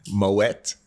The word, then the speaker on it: Moët: Louis Vuitton Moet Hennessy. Pronounce the T in Moet, please.